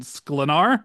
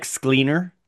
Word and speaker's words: Sklenar: It's an old-school, high-concept thriller starring Megan Fahey and Brendan Sklenar. Did I pronounce that correctly? – You're the first person to say it right.